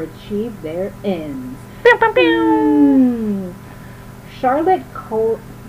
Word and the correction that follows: Corday: It's like the easiest thing to say, and I can't. It's fine. Charlotte Corday. Yeah, C-O-R-D-A-Y.